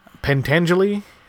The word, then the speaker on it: Pentangeli: So, in the meantime, we have a little bit of fun stuff with Frank Pentangeli.